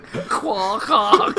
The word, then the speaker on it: Quahog: Quahog. – I thought I was right. – He thought he nailed it. He thought Quahog was in the Arctic.